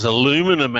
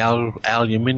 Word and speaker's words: aluminium: Same as aluminum and aluminium. All these different, you know, we say aluminium. – Well, aluminium puts an I after the second N. It's actually an added vowel.